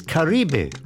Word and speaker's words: Kribi: That's K-R-I-B-I, Kribi. But if you pronounce it, it's Karibe, right?